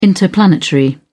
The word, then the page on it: interplanetary: British English